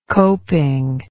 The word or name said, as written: coping